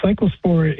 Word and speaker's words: cyclospora: Is it cyclospora? Am I saying that correctly? – Yeah, cyclospora is a parasite that is the agent that causes the disease.